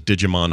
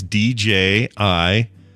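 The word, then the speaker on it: Djimon: I would have thought so too, because it looks like Digimon Hansu is who he's talking about, and all he's doing is just pronouncing the D that's at the beginning of that name. – That's right. Here's this Wikipedia page. As you can see, it's Digimon, which means that actually translates directly to.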